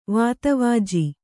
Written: ವಾತವಾಜಿ

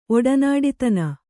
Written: ಒಡನಾಡಿತನ

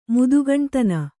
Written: ಮುದುಗಣ್ತನ

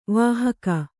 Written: ವಾಹಕ

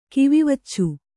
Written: ಕಿವಿವಚ್ಚು

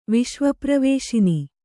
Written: ವಿಶ್ವಪ್ರವೇಶಿನಿ

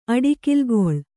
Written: ಅಡಕಿಲ್ಗೊಳ್

